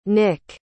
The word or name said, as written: nic